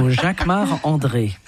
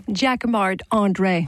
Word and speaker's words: Jacquemart-André: You say it like a Frenchman. – Of course, because I'm French. So Jacquemart-André. – And say it so we can understand it. – Jacquemart-André.